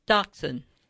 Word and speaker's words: dachshund: Dachshund. – That sounds pretty good. – Is it sort of like an X? – Yeah, that's sort of. Dachshund.